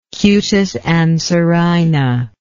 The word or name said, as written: cutis anserina